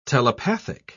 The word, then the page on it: telepathic: British English